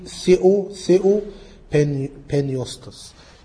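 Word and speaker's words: ثيو بينيوستوس (θεόπνευστος): هو ثيو المفروض تتنطق ثيو بينيوستوس.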